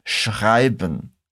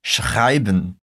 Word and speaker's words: schreiben: Presta bastante atenção agora nos sons que nós temos na palavra schreiben. Primeiro, temos um som bem forte de sh. Depois, aquele som de R lá da garganta.